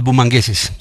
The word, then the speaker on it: bumangueses: Pero de los bumangueses. Se dice así, Andrés. – Bumangueses. – ¿Ibas a decir bucaramangueses? – No, es que estaba probando.